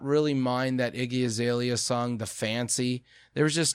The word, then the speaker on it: Azalea: But one of the trending videos was, how do you pronounce her name? It's, I know Iggy, but is it Azalea? – Yep, Iggy Azalea. – Iggy Azalea. – And not Iglesias.